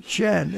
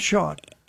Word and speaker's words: Shan: It's Shan, actually, Shan, which refers back to storyteller. Shan is pronounced, not Sean. – Tommy, you're overruled. – Did Tom say the other? – Yeah, Sean, like my brother Sean.